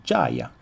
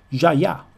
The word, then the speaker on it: Djaya: D-J-A-Y-A, Jaya, Jaya probabilmente, visto che è francese, e ascoltiamoci subito questo The System.